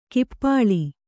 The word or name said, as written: ಕಪೋಳಿ